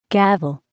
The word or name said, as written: gavel